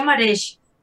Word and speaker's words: Marech: Eh, se pronuncia Marej.